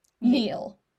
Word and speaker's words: Niall: They have a serious, brooding, older son, Simon, and their younger, carefree Niall. Neil. – Neil? – Neil. I said that wrong.